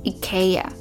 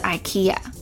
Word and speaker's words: IKEA: IKEA 或是 IKEA，这两个念法，一个是瑞典的念法，一个是美式发音的问的念法。